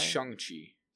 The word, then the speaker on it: Shang-Chi: Oh, also, I should say, apparently that's not how you say his name. – Oh, it's like Shang-Chi? – I think it's Shang-Chi. Maybe we should try to start doing that from now on. – Shang-Chi? – Yeah.